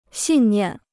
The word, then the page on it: 信念: xìn niàn